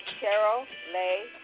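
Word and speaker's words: Cheryl Leigh: The address is 1698 Carol Lay Drive. The street is spelled C-H-E-R-Y-L. Second word is L-E-I-G-H Drive.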